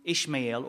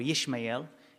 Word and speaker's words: Ishmael: So Ismail, whether you say it's in Hebrew or it's in Arabic, because the reason why we know it's pronounced Ishmael or Yishmael is in Hebrew, but Ismail is in Arabic.